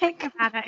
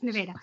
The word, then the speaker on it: Nevada: The town next to ours is called Nevada, because we can't say that right either. It's not Nevada, it's Nevada.